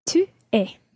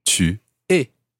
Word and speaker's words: tu es: Tu es. – Tu es.